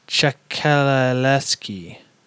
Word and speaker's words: Ciesielski: His name was Andrej Ciesielski. – Great pronunciation. – I mean, can you pronounce that? Go for it, you Polak. – Oh, easy there, buddy. – Ciesielski.